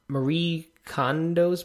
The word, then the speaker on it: Marie Kondo: So we each have read recently Marie Kondo's book.